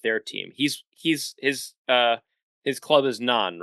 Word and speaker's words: Nantes: I don't think they pronounce the T in the T-E-S. So I think it's pronounced Non.